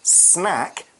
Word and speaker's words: snack: Umi Mala finishes by saying, India also has thousands of snacks.Now remember, you pronounce this word snack with the same sound as black. You don't pronounce it like snake.